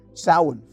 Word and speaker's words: Samhain: Samhain, for example, and yes, that's how it's pronounced, is observed on the evening of October 31st.